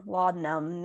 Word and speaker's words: laudanum: I said laudanum. Laudanum, yeah. – Yeah. – I feel like in every Tolstoy book I read, it's like, you know, give me some laudanum.